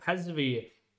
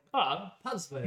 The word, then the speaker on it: Pasveer: His name is Remco Pasveer. That's not how you say it. – Pasveer.